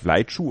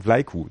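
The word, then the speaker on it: Vlaicu: Und dann habe ich gedacht, hier Florian Vlaiku, vielleicht Vlaichu oder so. Nein, Vlaiku. Das C wird wie ein K ausgesprochen.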